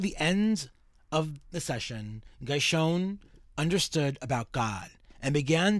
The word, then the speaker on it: Guychone: It's spelled E-D-O-U-A-R-D, Edouard. – Oh, Edouard. – G-U-Y-C-H-O-N-E. – Guyshon.